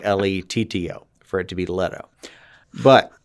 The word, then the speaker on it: Leto: Is it Leto or Leto? – Leto.